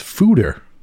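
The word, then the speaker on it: foeder: That word is pronounced fooder.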